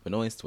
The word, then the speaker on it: Benoist: Is it Benoist? Whatever it is. – I say Benoist. – Benoist. Benoist does sound much more elegant. And Benoist sounds like moist, which I know pisses off a lot of people.